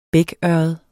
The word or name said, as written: bækørred